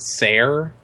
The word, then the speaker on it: Ser: No, so like, I guess it's Sir, which is S-E-R, but like he pronounces it Sair.